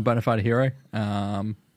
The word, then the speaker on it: BonaFideHiro: So I'm BonaFideHiro. It's exactly the way it's spelled, but it's an I in hero instead of an E.